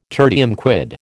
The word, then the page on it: tertium quid: American English